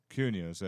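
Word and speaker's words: Cuneo: The I can't really pronounce those. Keones and the Strassies. – Cuneos, yeah, there you go.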